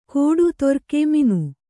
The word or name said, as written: ತರ್ಗಿ